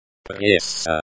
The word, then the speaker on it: пресса: Пресса. Пресса. This word refers to the press, media, or news organizations. The stress is on the first syllable.